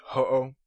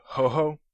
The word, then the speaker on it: Ho-Oh: Another more commonly mispronounced name is Ho-Oh. It's spelled H-O-O-H. Some people say Ho-Ho, like a Twinkie or something, if you know what I'm talking about, those little Hostess Twinkies, and then there's other ones called Ho-Hos.